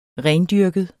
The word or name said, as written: rendyrket